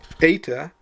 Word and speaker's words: eta: Eta is E as in obey. It's a long E sound.